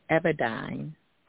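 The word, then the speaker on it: Evadyne: It's pronounced Everdyne pharmaceutically. Everdyne.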